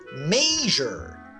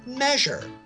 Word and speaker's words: measure: It's the word measure. Many here mispronounce it measure. Again, I cannot find a single dictionary which has measure as the pronunciation for measure.